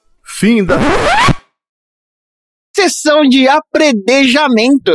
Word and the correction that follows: Apedrejamento: Eu falei errado. Apedrejamento.